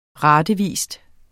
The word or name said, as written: ratevis